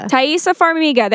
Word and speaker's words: Taissa Farmiga: Taissa Farmiga.